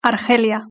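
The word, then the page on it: argelia: European Spanish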